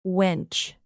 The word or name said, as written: wench